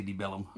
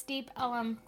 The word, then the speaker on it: Deep Ellum: That's how you say it. You say Deep Ellum. – No, you don't. – Yeah, you do. It all runs together.